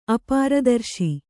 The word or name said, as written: ಅಪಾರದರ್ಶಿ